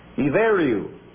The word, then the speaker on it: Ibheriu: One of the ancient names of Ireland is I-B-H-E-R-I-U. Pronounced I-V-E-R-I-U. Iveru. But that's the way it's spelled.